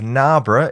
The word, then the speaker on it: Narborough: Firstly, my pronunciation of Narborough is apparently perfect, which was a relief to hear.